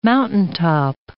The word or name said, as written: mountaintop